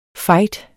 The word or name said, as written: FIGHTERS